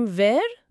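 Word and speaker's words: vert: مثلا تو کلمه ور وقتی برای اسم مذکر به کار میره، اون ت آخرش اصلا تلفظ نمیشه. – ا؟ پس فقط میگیم ور؟ – بله، همین جزئیات کوچیک تفاوت بزرگی ایجاد میکنه.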